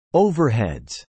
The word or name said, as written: overheads